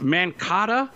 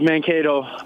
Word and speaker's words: Mankato: Is it pronounced Mankata or Mankato? How do you pronounce that? – It's pronounced Mankato, Minnesota Mankato.